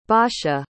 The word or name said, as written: basia